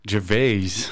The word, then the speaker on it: Gervais: Gervais sorry.